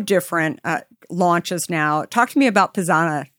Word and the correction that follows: Pizzana: Is it pronounced Pisana? – You're very close. It's pizzana. – Pizzana.